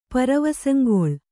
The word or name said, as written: ಪರವಸಂಗೊಳ್